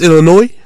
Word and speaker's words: Illinois: It is S in Illinois. – You don't pronounce it. – It's Illinois? – Yes.